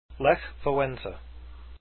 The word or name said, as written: Walesa